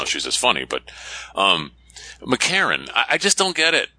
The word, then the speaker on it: McEachern: You know, there are a lot of names like that, but it's spelled McEachern, E-A-C-H-E-R-N. Even at the end, we say McArron. It's like there's nothing between the R and the N.